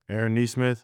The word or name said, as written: Aaron Nesmith